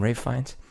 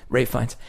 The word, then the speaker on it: Ralph Fiennes: The way that Ralph Fiennes, is that how you say his name? Ralph Fiennes? Even though it's spelled like Ralph. – Yeah. – Yeah, Ralph Fiennes.